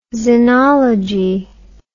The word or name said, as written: zenology